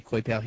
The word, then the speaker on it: Coipel: The Mighty Thor number one, written by Matt Fraction, art by Olivier Coipel. That's how you pronounce his name, Olivier Coipel. He's French.